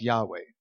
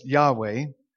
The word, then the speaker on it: Yahweh: we think it's pronounced something like Yahweh.